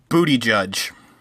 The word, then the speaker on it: Buttigieg: I think it's Pete Buttigieg. And it sounds like a joke, but I'm pretty sure that's actually how it's pronounced. – Buttigieg.